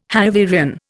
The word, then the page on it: Hanoverian: Indian